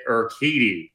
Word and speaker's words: Urquidy: Looking at game three, we have Chris Sale against Jose Urquidy.